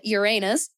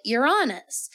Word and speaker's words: Uranus: I promise you won't be laughed out of a lecture or anything for saying Uranus, including to a fucking scientist. Like, you are very welcome to pronounce it that way. And then you may have heard people pronounce it Uranus. And this is also very, very common and potentially a more traditional way of pronouncing it.